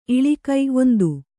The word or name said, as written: ಇಳಿಕು